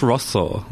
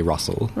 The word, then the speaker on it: Rosso: It's pronounced Rosso, which is Italian for red. – Okay. – Most people who can't pronounce it just say Russell.